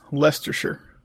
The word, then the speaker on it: Leicestershire: It's pronounced Leicestershire. – Leicestershire. Yeah. So it's not what it says, but okay. hey, it's English. Don't fight.